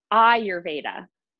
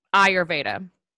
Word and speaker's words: Ayurveda: Today's topic, if I'm saying it correctly, you have to tell me, is Ayurveda. – Close, very close. – Okay. – Ayurveda. – Ayurveda.